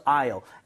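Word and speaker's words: aisle: So I'll. S는 발음하지 않습니다. 그래서 이게 통로 자리가 되겠고요.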